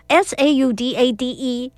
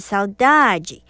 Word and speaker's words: saudade: 那也顺便跟听众朋友分享一下，我有很认真的去研究一下哦，这个 SAUDADE 到底是要怎么发音呢？如果你是读巴西葡语啊，常用的发音听起来像是 saudade，如果你是用欧洲的葡语，也就是葡萄牙文的葡语，听起来比较像是 saudade，所以很好玩。